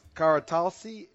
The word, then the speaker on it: Karatasli: We're here with Sahan Savas Karatalsi. Am I pronouncing it right? – That's right.